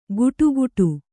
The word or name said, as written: ಗುಡುಗುಡು